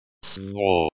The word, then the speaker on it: свобода: The word is stressed on the first syllable. Svoboda.